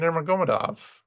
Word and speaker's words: Nurmagomedov: Saeed Nurmagomedov. Or Nurmagomedov. I shouldn't trip through that, apparently. – That's your favorite name to mispronounce. – And it's just because, like, I feel like I'm getting it right. It's, you know, Saeed Nurmagomedov. – But treat the Nur as like a prefix on the real name, which is Muhammadov, basically.